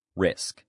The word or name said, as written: risk